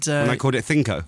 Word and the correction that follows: CNCO: You were pronouncing it, trying to do the authentic sort of accent on it, but going, that's Little Mix there, it's and Senko. – It's just CNCO.